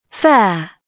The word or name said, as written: fair